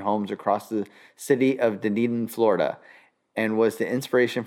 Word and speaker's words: Dunedin: Dunedin, yes. – That's how you say it? – It's okay. A city, yeah. – I've never known if it's Dunedin or Dunedin. – It's like Crocodile Dundee.